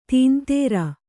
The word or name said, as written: ತಂದ್ರ